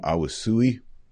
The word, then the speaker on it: Aoussaoui: What we're hearing is there's a Muslim immigrant, 20-year-old Brahim Awusui, that's the right way to say it.